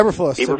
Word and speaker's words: Eberflus: Is it Eberflus? Eberflus? How do you pronounce it? – Yeah, Eberflus.